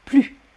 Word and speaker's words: plus: Là, on ne prononce pas le S, on prononce plus.